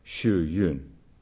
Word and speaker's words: Hsu Yun: That's spelled H-S-U-Y-U-N, and those U's are pronounced pretty much like the German U-Umlaut, Xu Yun.